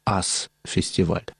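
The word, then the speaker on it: US Festival: В этом месте уже и до этого проводились рок-фестивали, которые имели название «Ас-фестиваль». Пишется как «US-фестиваль».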